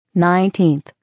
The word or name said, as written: 19th